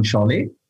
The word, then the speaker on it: Saleh: Ähm, Saleh ist geschrieben, richtig ausgesprochen heißt es dann Schale, ist ein türkisches Geschlecht.